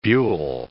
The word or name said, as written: buhl